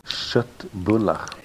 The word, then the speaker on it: Köttbullar: And I think it's called Chatbula. How do you pronounce it correctly? – Köttbullar. – Köttbullar.